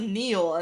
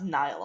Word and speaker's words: Niall: I thought it was Neil, and then it's like, no, it's pronounced Niall.